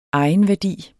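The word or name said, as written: egenværdi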